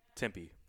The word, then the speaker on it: Tempe: to Temp. – Arizona State. And is it Temp or Tempe? – Tempe. – Okay. – Yeah, Tempe. – Been saying that wrong for a long time.